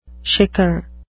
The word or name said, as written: shicker